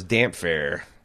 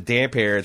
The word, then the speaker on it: Damphair: What's funny is there's a lot of people wrote me said I always assumed that his name was Damphair, because it's the P and H together making the phonetic F. – Oh, okay. – And like the fact that you pronounce it damper, it's like a lot of people like smack their foreheads.